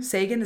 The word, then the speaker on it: Sagan: Sagan, is that how you say it? – Yeah.